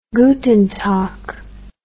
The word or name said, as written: Guten Tag